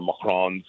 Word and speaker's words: macaron: Not Macron, the former prime minister, but Macron, macaron.